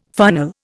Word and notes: funnel: Indian

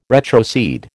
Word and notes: retrocede: American English

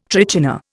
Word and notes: trichina: Indian

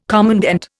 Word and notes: commandant: Indian

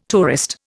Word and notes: tourist: Indian

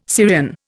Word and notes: Syrian: Indian

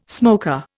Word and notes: smoker: Indian

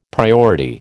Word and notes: priority: American English

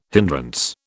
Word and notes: hindrance: British English

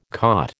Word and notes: caught: American English

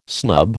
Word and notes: snub: American English

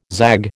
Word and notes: zag: American English

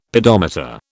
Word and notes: pedometer: British English

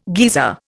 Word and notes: geezer: Indian

